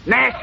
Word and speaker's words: gnash: Nash is spelled G-N-A-S-H, and the Y is silent preceding the N.